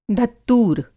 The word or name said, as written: धत्तूर